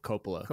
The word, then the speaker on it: Coppola: Coppola. – Coppola. Makes sense. – Yeah, because, you know, what I gathered. – Yeah. You just think of his work, and they actually, like, Coppola as a filmmaker and Nick Cage as an actor are actually not that far off.